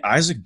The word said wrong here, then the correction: Isak: I mean Isak, I believe it's pronounced Isak.